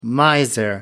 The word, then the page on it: miser: American English